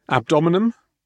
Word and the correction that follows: abdomen: Again, you really struggle with that one, don't you? You really want to throw in another syllable. – Abdomen? I can't say abdomen. Abdomen.